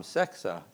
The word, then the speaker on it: Sexe: It was Tom Sexa. That's how at least you would pronounce it in Norwegian, S-E-X-E.